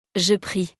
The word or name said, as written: je prie